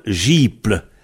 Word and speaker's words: jibl: J-I-B-L. Du sable, gible. Le B, ça prononce P en final, donc ça fait gible, mais ça s'écrit gible, comme pauple, qui s'écrit pauble, quand on a beauple.